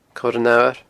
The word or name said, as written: coiranfhamhair